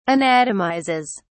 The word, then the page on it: anatomizes: British English